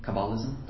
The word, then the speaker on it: Kabbalism: Their name was Riley, but since then they've gone through a name change, and I think that they are now practicing, correct my pronunciation if it's wrong, Kabbalism? – Mm-hmm. – Kabbalism, that's how it is? – Yep.